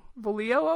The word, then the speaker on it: Volio: So it's Volio.